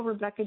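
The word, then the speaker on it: Rebekah: So if you Google Rebecca Jones for Congress, it'll come up.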